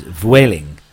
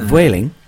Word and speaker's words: Vueling: And she's put at the top of her message to her, she's put here, "By the way, here in Italy, they pronounce Vueling like the English word whaling, with a V in front of it, which is Vueling." – Yeah. – So, Vueling, that's how you're supposed to say it in Italy.